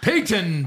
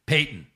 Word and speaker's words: Peyton: And it's a throwaway T. It's Peyton.